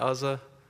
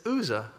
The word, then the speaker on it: Uzzah: I grew up pronouncing it Uzzah, but it's Uzzah. I learned that the other day.